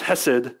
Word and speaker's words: hesed: In the Hebrew word, the word steadfast love is pronounced hesed, and it conveys this idea of loyal relational love.